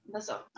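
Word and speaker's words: L'Oiseau: But the wine I ended up going with today was Chateau Liesso. Did I say that right? – Let me see. – I'm trying to learn French. It's spelled L and then a little apostrophe O-I-S-E-A-U. – Chateau Loiseau. – Loiseau. Okay, I was close.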